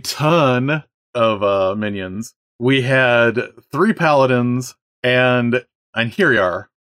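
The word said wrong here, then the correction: Einherjar: which I'm probably mispronouncing, but basically the honored dead of Valhalla that have been ascended. – The Einherjarin? – Okay, that's entirely possible. – I only know that that's a pronunciation because it comes up in the Dresden Files audiobook all the time, and I've heard James Marster say it repeatedly, so that's why I know it's Einherjarin.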